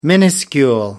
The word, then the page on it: minuscule: British English